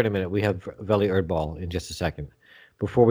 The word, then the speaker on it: Welle Erdball: Welle Erdball.